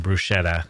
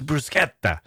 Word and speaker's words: bruschetta: Like an American would come to visit and say, "Oh, I'll have some bruschetta," and someone would say, "Ah, it's bruschetta."